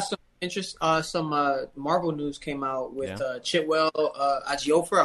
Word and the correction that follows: Chiwetel Ejiofor: I hope I said his name right. He plays Baron Mordo in Doctor Strange. – Yeah. – Oh yeah, Chiwetel Ejiofor.